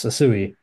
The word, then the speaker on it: Asui: One that he had been reported he visited last week, 19-year-old Italian, Elise Asui. Is that anywhere close to the pronunciation? – Yes, Asui, or at least so they tell me in the Italian commentary.